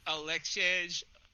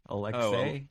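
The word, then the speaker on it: Aleksej: Alexej. – No, I think the J makes a Y sound. Alexej? Oh, Alexej Pokusevsky? – Yes, I believe that's correct.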